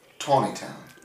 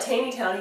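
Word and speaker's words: Taneytown: We've got one near us called, and they call it Taney Town. – But it's spelled the same way. T-A-N-E-Y, town.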